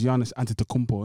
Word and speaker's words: Giannis Antetokounmpo: I hear that, but what I'll say is, okay, compared to another Greek resident who happens to be black, he's Giannis Antetokounmpo, isn't it? Did I get that? I think I butchered the surname. – I think you got it about right.